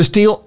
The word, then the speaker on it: Stihl: Steel is spelled S-T-I-H-E-L. Not like, you know, Steelers or going to steal something, but S-T-I-H-L, steel.